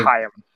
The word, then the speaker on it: Chaim: Chaim.